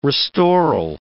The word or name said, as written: restoral